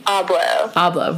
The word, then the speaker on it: Abloh: Abloh.